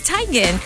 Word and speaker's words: Teigen: Like, see, Chrissy Teigen. Which we know as Chrissy Teigen. – Oh, it's Teigen? – It's Teigen. Chrissy Teigen. – Does she say it as Teigen? – Yeah, it's Teigen.